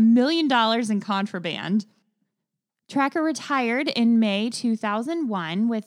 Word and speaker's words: Trakr: And I hope I'm saying it right because it's spelled T-R-A-K-R. But I'm pretty sure it's pronounced Tracker.